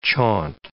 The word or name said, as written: chaunt